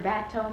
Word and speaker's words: bateau: Does the term bateau mean anything to you? – Mm-hmm.